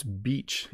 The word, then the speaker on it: beach: The reason I want to answer this question, even though bitch is considered a bad word, is because people who are learning English often mispronounce beach.